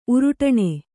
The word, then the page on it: ಉರುಟಾಣಿ: Kannada